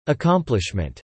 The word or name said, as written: accomplishment